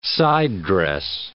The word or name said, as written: side-dress